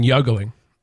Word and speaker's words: juggling: We really should because right now we're juggling these different projects that would drain my 401k account. – You mean juggling? Soft J.